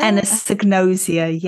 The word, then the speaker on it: anosognosia: Anosognosia? – Anosognosia, yes.